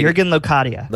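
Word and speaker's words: Jürgen Locadia: But Locadia? Jurgen Locadia? – Jurgen Locadia. – Locadia. Okay. So I was close.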